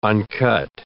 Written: uncut

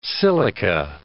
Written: silica

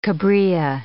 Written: cabrilla

